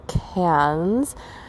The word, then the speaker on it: Cairns: So tomorrow, as you know, if you've been listening to the podcast, I'm flying up to Cairns, as they call it. It's spelled C-A-I-R-N-S, to the north of Australia, and it's going to be warm and jungly up there in tropical weather.